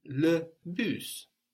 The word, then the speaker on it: le bus: For example, the bus is le bus, the bus. – Le bus. – So that's spelled just like in English, B-U-S, but we pronounce that S on the end.